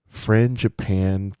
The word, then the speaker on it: Frangipane: At the Contemporary, yeah, Contemporary Resort at the Contempo Cafe, you've got the Holiday Cranberry Frangipane Cake. Did you say that right? – Mm-hmm.